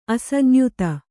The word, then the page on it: ಅಸಂಯುತ: asamyuta